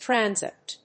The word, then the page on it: Transept: tran・sept; trˈænsept; American English